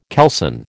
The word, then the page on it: kelson: American English